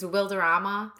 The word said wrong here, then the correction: Wilmer Valderrama: Wilmer Valderrama.